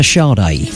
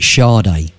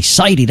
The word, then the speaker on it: Sade: And he never pronounced it Sade. He'd say, Sadie, that bloody Sadie, he'd go.